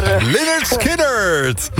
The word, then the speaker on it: Lynyrd Skynyrd: Free Bird met, zijn naam kan ik niet uitspreken, maar... – Lynyrd Skynyrd! – Yes. – Ja, oké, Lynyrd Skynyrd.